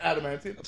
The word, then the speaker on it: Adamantium: Adamantium? – Thank you.